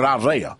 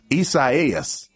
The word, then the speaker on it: Isaias: He said, I'm just going to call it Isaiah. – That sounds about like, right? – That's what he said. – That's like that lady said. – But it's pronounced Isaias.